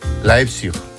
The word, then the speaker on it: Leipzig: ¿Cómo se dice? – Leipzig. – Leipzig. – Leipzig. – Leipzig, muy bien.